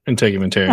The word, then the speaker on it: integumentary: Integumentary.